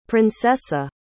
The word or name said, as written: princesa